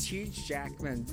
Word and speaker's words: Huge Jackman: Am I saying that right? – It's actually Huge Trackman. – It's Huge Jackman, but is it like, it actually should be Huge Trackman at this point, because that's my Instagram handle.